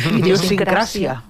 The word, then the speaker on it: idiosincràcia: ¿Cómo se dice? – Idiosincràcia.